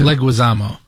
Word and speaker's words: Leguizamo: Who's this guy, the Odyssey, John Leguizamo? Is that how you pronounce it? – Leguizamo. – Leguizamo. Leguizamo.